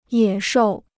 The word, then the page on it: 野兽: yě shòu